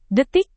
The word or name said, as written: detik